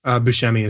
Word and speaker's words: Buscemi: Steve Buscemi was Buscemi, how do you say that? – Buscemi is how I've always said it.